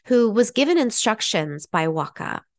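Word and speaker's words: Waaqa: So they tell a story about their god, and I'm going to spell it in case I am mispronouncing it, but I believe it is the Waka. It's W-A-A-Q-A.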